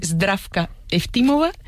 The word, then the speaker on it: Zdravka Eftimova: Ayúdanos a pronunciar, los autores. – A ver, Georgi Gospodinov, ¿sí? – Zdravka Eftimova y Vera Mutavcheva.